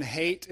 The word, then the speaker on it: Haidt: Jonathan Haidt is a researcher, H-A-I-D-T is how his name is spelled, so that would be ironic, right, if his name meant Haidt. I didn't even think about that until I just said it.